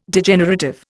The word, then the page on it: Degenerative: Indian